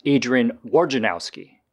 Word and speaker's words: Wojnarowski: His nickname is Woj, but his name is pronounced Adrian Wojnarowski.